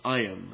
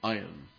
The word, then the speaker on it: Ayin: Oh, I'm sorry, the letter is Ayin. That's how we pronounce that. Ayin.